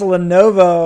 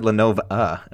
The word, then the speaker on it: Lenovo: It's Lenovo. – Oh, I thought you said Lenovo, and I was like, I might have said Lenovo. – That's not how that company is pronounced.